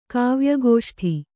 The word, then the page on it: ಕಾವ್ಯಗೋಷ್ಠಿ: Kannada